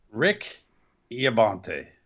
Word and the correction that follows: Ian Riccaboni: Ian Riccaboni.